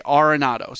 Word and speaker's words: Arenado: And she would say Arenado.